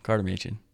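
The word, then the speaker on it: Karter Machen: Just my name, Carter Machen.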